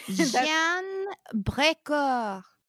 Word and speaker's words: Jeanne Brecourt: Yeah, so that's the story of, I'm going to say her name because I've said it wrong 50 million times. – Jeanne Brecourt. – Yay.